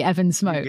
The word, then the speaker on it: Evan Smoke: Did I say it correctly, Evan Smoke? – Yeah, you're on a roll.